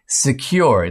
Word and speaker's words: Sekur: Secure, it's spelled S-E-K-U-R,